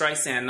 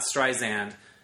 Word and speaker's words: Streisand: And she kept saying it's Streisand, not Streisand. Like, you really had to get a microscope to figure out the difference. It's a s, not z, apparently. It's Streisand with a s, not Simon with a z.